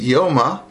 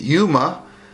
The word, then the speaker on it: Yoma: So, as an introduction, I've been pronouncing it Yoma. I normally, up till now, was pronouncing it Yuma, but the correct pronunciation, I think, after looking through a little bit, is Yoma, which is an Aramaic word,